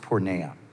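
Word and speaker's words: porneia (πορνεία): So sexual immorality is the translation of a Greek word that is pronounced porneia.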